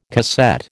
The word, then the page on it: cassette: American English